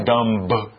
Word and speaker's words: dumb: No, you don't say dumb. It's just dumb.